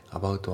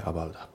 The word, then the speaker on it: About: で、発音ですけど、 About のところがリンキングで、さらに T が D で濁りますね。 About。 About はじゃなくて About。